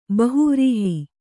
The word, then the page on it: ಬಹುವ್ರೀಹಿ: bahuvrīhi